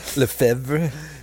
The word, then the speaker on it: Lefevre: How do you pronounce it? – Lefevre. – Yeah, Dutch Belgian.